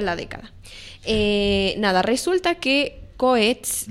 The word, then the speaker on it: Coez: Coetz. – Ok. – Coetz, que se escribe C-O-E-Z. – Z. – Coetz. – Exactamente. – En italiano, Coetz.